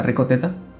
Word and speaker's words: Ricoh Theta: Por ejemplo, la Rico Z, que se escribe R-I-C-O-H-T-H-E-T-A, como teta, Rico Teta.